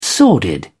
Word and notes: sordid: British English

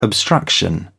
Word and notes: obstruction: British English

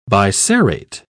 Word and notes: biserrate: British English

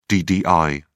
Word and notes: ddi: British English

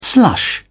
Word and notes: slush: British English

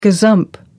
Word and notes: gazump: American English

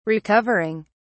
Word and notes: recovering: British English

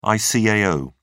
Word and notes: icao: British English

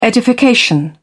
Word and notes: edification: British English

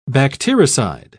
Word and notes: bactericide: British English

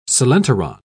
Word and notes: coelenteron: British English

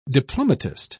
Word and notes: diplomatist: British English

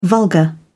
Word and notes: vulgar: British English

